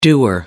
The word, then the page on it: doer: American English